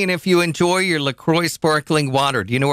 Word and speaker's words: LaCroix: Officially, it's pronounced LaCroix.